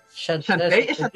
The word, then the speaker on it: Shantae: Shantae o Shantae. ¿Cómo se pronuncia ese nombre, weón? – Shantae.